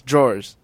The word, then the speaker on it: drawers: It with throwers, and it should rhyme with doors. – Drawers.